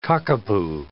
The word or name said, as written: cockapoo